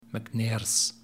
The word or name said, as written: MacNair's